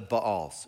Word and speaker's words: Baal: No sooner had Gideon died than the Israelites again prostituted themselves to the Baals, or the Baals. It's technically pronounced Baal.